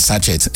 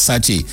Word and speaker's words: sachet: People say not sachet, but the correct pronunciation is sachet.